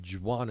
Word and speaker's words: Juan: The Juan is pronounced that way because it is French, not Spanish.